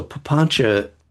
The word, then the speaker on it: papancha: Just for you to know that C, the letter C in Pali, is often pronounced as CH. And so papancha is translated as proliferation,